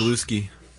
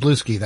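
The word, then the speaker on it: Bluesky: It's pronounced Blusky. – Yeah, and some people pronounce it Blusky. That's true.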